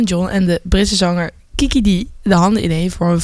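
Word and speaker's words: Kiki Dee: Zeg ik dat goed? – Kiki D. – Kiki D. – Kiki D. – Kiki D. Kiki D. Als in Kikaboe, maar dan Kiki D.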